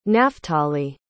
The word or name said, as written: naphtali